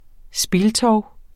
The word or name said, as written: SPILTOVENES